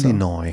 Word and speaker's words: Illinois: Illinois. – So? Illinois, das ist in, das S ist stumm. – Das ist, glaube ich, in Nordamerika irgendwo, ne? – Es ist in den USA. – Illinois ist ja ein Bundesstaat.